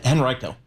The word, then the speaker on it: Henrico: Henrico? – Henrico.